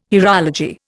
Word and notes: hierology: Indian

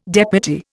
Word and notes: deputy: Indian

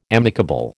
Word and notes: amicable: American English

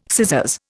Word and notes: scissors: Indian

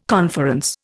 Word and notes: conference: Indian

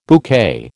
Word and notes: bouquet: American English

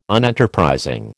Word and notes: Unenterprising: American English

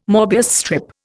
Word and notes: MÔbius strip: Indian

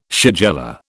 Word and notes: shigella: British English

